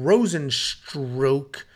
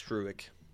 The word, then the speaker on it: Rozenstruik: We have Jairzinho Rosenstroke. I say Rosenstroke, but he just tweeted exactly how to pronounce it. And I I think it's Struick.